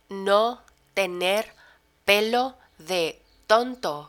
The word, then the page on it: no tener pelo de tonto: spanish